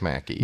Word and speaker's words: Mackey: In the article, McKay, who I guess is the John McKay or Mackey, maybe that's the way you pronounce his name. – I think he says Mackey. – Mackey, yeah.